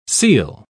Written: ceil

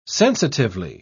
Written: sensitively

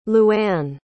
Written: luann

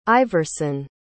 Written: Iverson

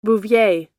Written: bouvier

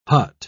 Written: hut

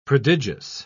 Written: prodigious

